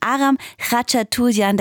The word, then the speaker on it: Aram Khachaturian: Es genauer gesagt wird der Name ausgesprochen Aram Khachaturian.